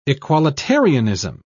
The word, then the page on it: equalitarianism: British English